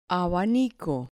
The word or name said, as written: abanico